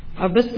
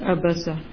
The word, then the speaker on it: عبس: تیسویں پارے میں آج جو سورت ہمارے سامنے ہے وہ ہے سورت عبس عبس تو نہیں کہیں گے سورت عبسا. بعض دفعہ ہم اردو میں پرونانس جب کرتے ہیں تو سورت عبس کہتے ہیں, فرق ہوتا ہے معنی, اور خاص طور پہ جب آپ عین کو کچھ اور طرح بول دیں تو معنی بالکل بدل جائیں گے.